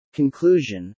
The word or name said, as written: conclusion